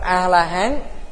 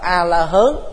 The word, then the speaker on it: A-La-Hán: Miền Bắc đọc là A-La-Hán, miền Nam đọc là A-La-Hớn.